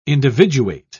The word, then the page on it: individuate: British English